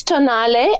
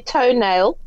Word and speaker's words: Tonale: Yes, it's Tonale. And some people say toenail. And no, it's not a toenail. So no. – It's Tonale, and it's named, I think, after an Italian pass, a mountain pass in Italy.